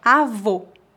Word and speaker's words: avô: como nas palavras avó e avô. Eu tenho uma dica para ajudá-lo. O O fechado pronuncia-se como a letra O na palavra old em inglês. Old, avô.